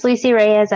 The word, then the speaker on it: Lucy Reyes: So my name is Lucy Reyes, as you mentioned. You did pronounce it right.